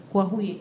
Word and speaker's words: Coahuila: espero haberlo pronunciado bien. – Coahuila, Coahuila, ok.